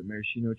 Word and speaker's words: Maraschino: So it's got like Reese, Mara. How do you say it? – Maraschino cherries? – Maraschino cherries with stems.